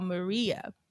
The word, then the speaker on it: Miiriya: So there is a company called Maria, but it's spelled M-I-I-R-I-Y-A.